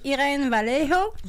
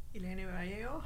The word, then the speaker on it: Irene Vallejo: Voilà, donc je te laisse prononcer le nom de l'autrice avec l'accent espagnol. – Merci, de me mettre sous le projecteur. Alors, c'est Irene Vallejo. Oui, non? – Irene Vallejo.